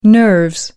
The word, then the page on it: nerves: British English